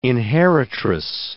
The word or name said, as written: inheritress